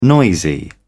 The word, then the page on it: noisy: British English